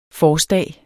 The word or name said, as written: forstag